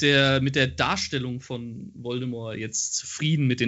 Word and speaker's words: Voldemort: Ähm, noch ein, ein Punkt, auf den ich gern zu sprechen kommen würde, und zwar ähm, Lord Voldemort und zwar Lord Voldemort, nicht Lord Voldemort, wie es im Film ausgesprochen wird. – Voldemort. – Äh, das T am Ende ist stumm.